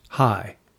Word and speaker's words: chai: The Hebrew word for life is C H A I, pronounced high.